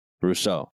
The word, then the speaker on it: Rousseau: Rousseau.